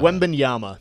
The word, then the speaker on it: Wembanyama: Yeah, I don't think anyone has come in outside of Wembanyama. Did I say it right? Victor Wembanyama. – Wembanyama.